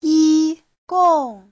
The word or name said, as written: yi1gong4